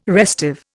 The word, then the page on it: restive: Indian